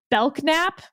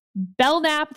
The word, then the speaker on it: Belknap: It is spelled Belknap, which is how I kept pronouncing it. But we have been reliably informed that it is, in fact, Belknap. The K is silent.